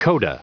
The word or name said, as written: coda